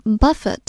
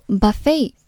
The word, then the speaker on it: buffet: 继续看下一个单词 buffeting，它的原型是 buffet，做动词表示反复的重击，strike something repeatedly and violently。这个单词做名词的时候读作 buffet，表示自助餐，我们之前有讲过。